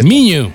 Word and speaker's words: Miño: En un entorno académico de la Universidad de Mijo, Mino, o cómo se dice. Miño. Miño. Miño.